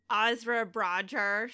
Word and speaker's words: Ezra Bridger: Ezra Bridger.